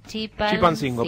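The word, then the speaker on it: Chilpancingo: Los temblores también se sintieron con mucha fuerza en Ciudad de México, Acapulco, Oaxaca, Guadalajara, Colima y Chipancingo. ¿Está bien? ¿Lo dije bien? Pero estoy sin anteojos. – Chipancingo, perfecto.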